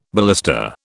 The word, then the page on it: ballista: British English